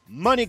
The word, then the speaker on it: Moni: Is it Moni or Money? I got to make sure I'm saying it right, because I really didn't know. – Money. – Money Grindhard Montana right here on K100 Radio.